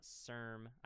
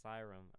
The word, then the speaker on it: Cyrm: And just because, in order to help, because you will never be able to spell these right on your own, that's O-X-N, pronounced Oxen, and then Sirum is C-Y-R-M. It's not CIRM. I thought it was CIRM for a while. I thought it was CIRM or something. It's pronounced Psy, C-Y, and then RUM. – RUM. – Sirum.